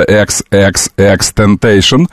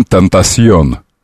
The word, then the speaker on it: XXXTentacion: Я напомню, что многие говорят Temptation, и даже в Википедии предлагается XXXTentacion, но и от странного образа написано по-испански, читается по-английски, но сам он в одном из интервью говорит, что он Temptation.